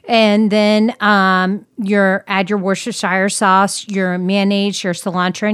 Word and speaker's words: Worcestershire: 1 teaspoon of Worcestershire sauce. I know, I'm not saying that right. – You know, Worcestershire sauce.